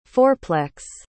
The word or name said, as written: fourplex